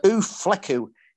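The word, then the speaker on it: U Fleků: So Ufleku, if I'm pronouncing it correctly.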